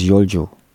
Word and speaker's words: Yolcu: Das ist, the spelling of Yolcu is Yolcu. – Genau, richtig. – And it's pronounced as Yolcu.